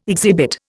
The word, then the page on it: exhibit: Indian